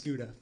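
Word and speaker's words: Skuta: It's Scuda.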